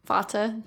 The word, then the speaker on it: Vata: Vata. – Vata. Whatever. I like Veda more. Vata. – Vata.